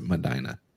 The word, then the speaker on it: Medina: And is it Medina, right? Medina, Tennessee. Is that what it is? – I guess so. – That's how you pronounce it, Medina.